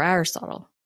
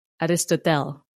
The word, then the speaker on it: Aristotle: My dad, after all, was a religious theologian, a student of the great thinkers and philosophers like Marcus Aurelius or Aristotle, which he pronounced as Aristotel. Sorry, Dad.